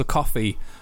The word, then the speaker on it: Ko-fi: But you can now buy us a coffee over at, I don't know if I'm saying it right, Ko-fi, K-O-F-I. – I don't know. – Coffee. – It's just that. It's coffee.